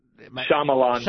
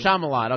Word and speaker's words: Shyamalan: Is that Shyamalan? – Shyamalan.